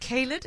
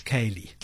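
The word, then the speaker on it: ceilidh: Is it Kaylid? Am I pronouncing that correctly? – It's Kaylee. – Kaylee. Okay, now I know.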